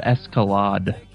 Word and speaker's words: Escalade: It's pronounced Escalade.